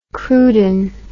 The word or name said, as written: cruden